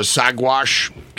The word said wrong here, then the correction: Saguache: Colorado? – Swatch. That's...